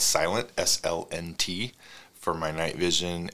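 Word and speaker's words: SLNT: And it's S-L-N-T, it's pronounced Silent.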